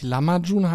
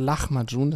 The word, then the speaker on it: Lahmacun: Und der hat mir nämlich beigebracht, dass es nicht Lahmacun heißt, wie ich es früher genannt habe, sondern Lahmacun. Das wird ja L-A-H geschrieben und C-U-N am Ende. Lahmacun. Aber ausgesprochen sagt er, wenn du zum Türken gehst und sagst, ich hätte gerne Lahmacun, dann lacht er schon, sagt er. Du musst Lahmacun sagen.